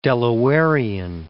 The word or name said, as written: Delawarean